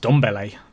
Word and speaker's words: Ndombele: So we had on Ndombele, but I think it's just Ndombele. It's not a silent N.